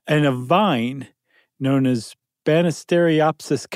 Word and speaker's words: Banisteriopsis: Yeah, but if it's it's pronounced like it looks. – Yeah, though. Banisteriopsis. – You got it.